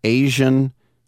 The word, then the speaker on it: AZN: We'll also, at 10:40, have Discovery Street Outlaws Asian and Farm Truck. Yes, it's spelled A-Z-N, and he pronounces it Asian.